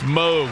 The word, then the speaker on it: Moog: It's Moog. Rhymes with Vogue. – Even though it looks like Moog, it's Moog.